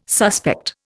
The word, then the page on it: suspect: Indian